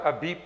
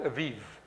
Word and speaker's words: Abib: It's spelled Abib, but it's called Aviv.